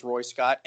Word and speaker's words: Roy Scott: you might think so, but a lot of times whenever I'm on the phone with someone like ordering something, I'll say my name is Roy Scott, and they will say, okay, they think it's Royce Ott. They think last name is fucking Ott.